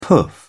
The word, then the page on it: poof: British English